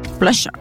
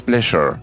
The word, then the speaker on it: pleasure: Pleasure. – Pues, mm, en realidad, esa pronunciación está muy mal. Te voy a explicar. – Pleasure. – Exacto, tiene que haber un vibrato. Es pleasure.